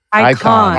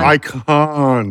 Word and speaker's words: Icahn: Icon. – Icon.